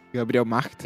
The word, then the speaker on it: Gabriel Macht: Gabriel Macht.